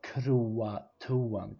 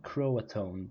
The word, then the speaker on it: Croatoan: Och det här ordet är alltså det som stod var Croatoan, Croatone tror jag det uttalas och Croatoan, C-R-O-A-T-O-A-N eh, och ordet kro, C-R-O på träd.